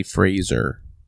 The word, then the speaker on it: Fraser: It's Fraser, mm-hmm. By the way. – I think you pronounce it Fraser. – It's most definitely Fraser.